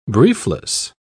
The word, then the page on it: briefless: British English